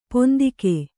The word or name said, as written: ಪಿಂತೆಗೆ